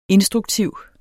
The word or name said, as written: instruktiv